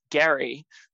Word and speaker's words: Gerry: It turns out that his name was actually pronounced Gary with a hard G rather than Jerry.